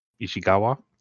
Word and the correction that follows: Ishiguro: Is it Ishiguro? – Ishiguro, close enough.